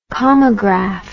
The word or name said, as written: kamagraph